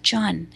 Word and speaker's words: jun: J-U-N. Rhymes with fun is how we call it.